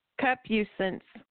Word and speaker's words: concupiscence: It's concupiscence is how you pronounce that.